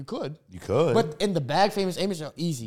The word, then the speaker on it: Famous Amos: Is it pronounced Famous Amos? – Yeah. – Ooh, good take. – Famous Amos.